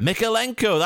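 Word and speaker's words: Mykolenko: Mikalenko, that's his name.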